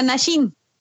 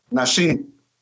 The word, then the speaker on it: Naxin: Nashin se deletrea N-A-X-I-N. – Perfecto. Y se pronuncia Nashin. Nashin.